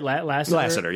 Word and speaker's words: Lasseter: And I mean, John Lasseter, am I saying that right? Lasseter. – Yep.